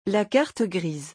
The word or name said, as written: la carte grise